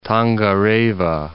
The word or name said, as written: Tongareva